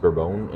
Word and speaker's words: bourbon: It's spelled like bourbon. If you ever see that on a bag, it's pronounced bourbon in coffee, and it's a type of coffee tree.